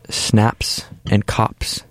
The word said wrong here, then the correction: Snops and Cops: Snops. Snops and Cops.